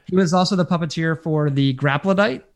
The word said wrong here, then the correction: Graptolite: or Graptolite, one of the Graptolites. I said it wrong again. We talked about the Graptolite. Graptolite.